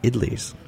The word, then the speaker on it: idli: how do you spell it, idlis? – I-D-L-I-S. – Okay. – Idli. – Gotcha. – I know, I kind of add an extra syllable to it.